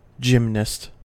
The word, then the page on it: gymnast: American English